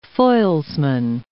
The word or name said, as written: foilsman